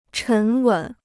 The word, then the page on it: 沉稳: chén wěn